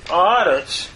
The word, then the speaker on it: aretz: And notice, eretz changes to aretz in pause at the end of the verse. The second to the last syllable is lengthened, or the vowel, from a segol to a kametz.